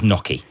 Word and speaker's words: gnocchi: It's actually gnocchi. – Oh, is it gnocchi? – Yeah. – I see, I'm so confident there. – N-Y, yeah. See, I'd have said gnocchi as well, but it's gnocchi.